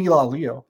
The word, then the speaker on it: Ilalio: and he said it's Ilalio. So he says people make it multiple syllables, not technically correct.